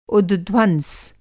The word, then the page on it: उद्ध्वंस: Hindi